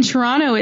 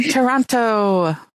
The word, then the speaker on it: Toronto: or that nobody told the women that the second tea in Toronto is silent? – Oh, I was going to ask you about that. We are in Toronto.